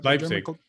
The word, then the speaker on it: Leipzig: They're with, Zegebig. How do you say the name that he's going to play with in the Leipzig? – There we go.